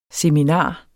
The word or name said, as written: seminar